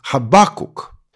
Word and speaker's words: Хабакук: Некоторые говорят Аввакум, другие говорят «Аввакум» с ударением на второй слог. Поэтому скажу пару слов об ударении. Вообще, строго говоря, произношение «Аввакум» более правильное. Кстати, если хотите уж совсем правильно, то вспомните, что в древнееврейском языке имя этого пророка оканчивалось на «к», а не на «м», то есть «Хабакук».